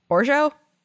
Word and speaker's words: Orgeat: It's O-R-G-E-A-T. So I think it's Orgeat. – O-R-G-E-A-T. So in French, you don't pronounce the T at the end because you just throw out a lot of consonants.